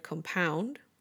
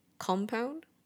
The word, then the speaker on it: compound: But this is one of those words where different syllables are stressed depending on whether you use it as a noun or verb. So with the noun and adjective, the first syllable is stressed, compound. With the verb, the second syllable is stressed instead, compound.